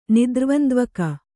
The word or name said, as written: ನಿದ್ರ್ವಂದ್ವಕ